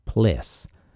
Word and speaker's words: PLSS: Next, they had to put on their backpacks, the Portable Life Support System, PLSS, pronounced PLESS, The Plessis contained a lithium hydroxide canister to remove CO2, and they supplied oxygen and cooling water, and associated pumps and fans.